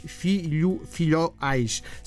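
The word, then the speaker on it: Fiolhais: Filhoais, assim é que se pronuncia.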